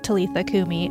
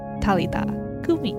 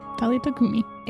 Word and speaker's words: Talitha Kumi: And I just would pronounce it Talitha Kumi, and that's not how it's pronounced. Talitha Kumi. oh, nice. Talitha Kumi.